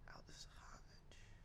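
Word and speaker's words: Aldis Hodge: Didn't really want to watch it for Kevin Bacon, but Aldous Hodge, I forget his name, but he was an actor on a show I watched, Leverage. – Aldous Hodge.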